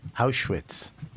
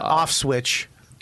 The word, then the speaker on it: Auschwitz: Auschwitz. Yeah. Auschwitz. – Not Auschwitz. – Yeah. – Not off switch. – What did you say, Auschwitz? – Well, you said Auschwitz.